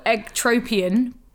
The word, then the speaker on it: ectropion: So uh, a cervical ectropion, we think, mm. is how it pro- pronounced um, and yeah.